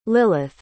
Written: lilith

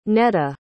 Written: nedaa

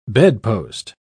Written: bedpost

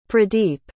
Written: pradeep

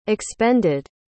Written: expended

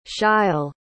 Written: sheil

